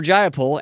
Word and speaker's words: Jayapal: It's a bad look for Jay Appel. I should look up how to pronounce her name real quick. Jay Appel. It's a bad look for Jayapal and those having signed this letter.